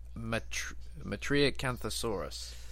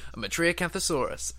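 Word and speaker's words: Metriacanthosaurus: So when I was writing this article, I was assuming that it was a Matriacanthosaurus. Did I say it right? – Yes. – Yeah, that's right. First time I've ever said that word. Matriacanthosaurus.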